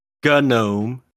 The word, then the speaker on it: GNOME: It's GNOME. – Yes. And anybody says, oh, it's not. Well, go look it up. It started as an acronym.